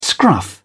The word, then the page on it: scruff: British English